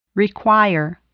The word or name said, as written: require